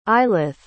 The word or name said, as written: ailith